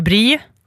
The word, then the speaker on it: brie: Okej, och hur ska uttalas då? – Nå, det är ju brie.